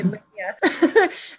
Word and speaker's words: Lenia: And my name actually means firewood in Spanish. It's spelled a little differently than the Spanish word, but that was the intent that my hippie parents had when they named me Lenia.